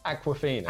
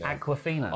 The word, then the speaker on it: Awkwafina: Awkwafina. – Awkwafina.